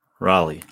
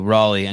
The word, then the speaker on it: Raleigh: Raleigh, like the city in North Carolina. – Thank you. Raleigh.